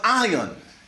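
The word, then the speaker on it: ion (αἰών): It's spelled I, I'm spelled A-I-O-N. It's pronounced ion.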